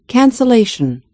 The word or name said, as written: cancellation